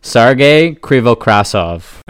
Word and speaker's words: Sergei Krivokrasov: Correct pronunciation is Sergei Krivokrasov.